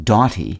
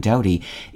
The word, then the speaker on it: doughty: One might assume that doughty should be pronounced doughty, paralleling similarly spelled words like bought and sought, or perhaps with a long O as in dough. But the vowel sound in doughty is the same as in the word doubt.